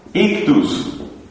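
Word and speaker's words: ichthys (ἰχθύς): Resulta que el pescadito en griego se dice ichthys, que se suele pronunciar así castellanizándolo un poco, ictus.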